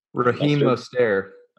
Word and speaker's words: Mostert: Mostert? – Robert Morset. Marstet. – Raheem Mostert.